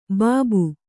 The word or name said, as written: ಬಾಬು